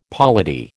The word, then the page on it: polity: American English